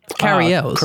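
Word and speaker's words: Cary Elwes: Like he's the guy in the room with Carrie Lewis? Is it a Lewis? How do you say his name? Guy from Princess Bride? – Carrie Ells.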